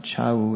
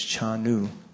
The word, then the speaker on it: Chanu: Also in this translation, it's not Chau, it's Chanu, but it can be pronounced differently in Bengali.